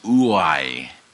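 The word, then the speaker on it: ouai (οὐαί): It's pronounced ouai. It's what you call an onomatopoeia. So an onomatopoeia is a word like buzz. It sounds like what it is.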